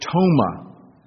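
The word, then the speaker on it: toma (πτῶμα): But this word that he uses here is the word toma. If those of you would like to write such things down, soma is S-O-M-A. This is silent P, P-T-O-M-A.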